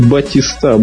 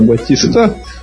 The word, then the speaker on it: Батиста: И вообще вот прям зрители, когда кричали «Батиста, Батиста» во время матча... – Именно с ударением на последний слог.